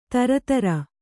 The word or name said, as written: ತರತರ